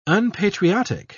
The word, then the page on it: unpatriotic: British English